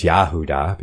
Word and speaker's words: Yahuda: which is the true Hebrew pronunciation for Judah, is Yahuda,